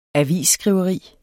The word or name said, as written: avisskriveri